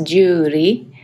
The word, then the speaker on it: Juri: Okay, so it's actually pronounced Juri. So the R is like a sound that is between R and D, so it's difficult to explain, but that's my name in Japanese.